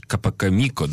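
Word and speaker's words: capocomico: Театр был такой антрепризный, театр премьерный, когда, вот э, ты правильно произносишь, Капакамико, да? Да. Капакамико.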